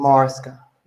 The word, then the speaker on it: Maresca: This guy, Enzo, I don't know how to say his last name, Marseille or Enzo Maresca. – Enzo Maresca.